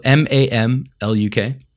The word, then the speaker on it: Mamluk: Mamluk, M A M L U K. – Mamluk, I uh, buy that.